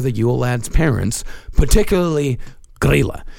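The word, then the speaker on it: Grýla: These I know I'm actually pronouncing right. – Okay. – There is Grilla and Lepaluthe. – Huh. – Yeah. Grilla and Lepaluthe.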